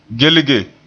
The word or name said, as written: gelge